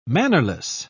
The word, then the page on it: mannerless: British English